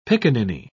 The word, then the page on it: piccaninny: British English